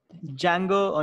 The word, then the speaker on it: Django: Django Unchained, The D is Silent, Beast of the Southern Wild.